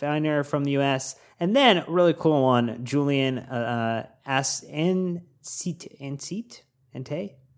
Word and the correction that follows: Anstey: Yeah, but here's what you butchered his name. – Yeah, I did. I butchered it. That's going to be my new nickname. This really embodying the Daniel Day-Lewis. – Anstey. Yeah, you got it.